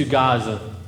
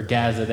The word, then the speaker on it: Gaza: Gaza, or Gaza, they pronounce it both ways.